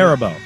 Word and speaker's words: Haribo: Haribo.